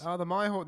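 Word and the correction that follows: mHawk: The Mihawk. – The M-Hawk. – The M-Hawk. – The M-Hawk 2.2.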